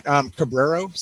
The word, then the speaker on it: Cabrero: Mike, how do you say your last name? I don't want to screw it up. – That's all right. Cabrero. So C-A-B-R-E-R-O.